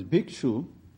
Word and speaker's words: bhikshu: Sanskrit word, actual word is bhikshu, which has been in Pali been pronounced as bhikkhu.